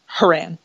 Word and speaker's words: Horan: Horan.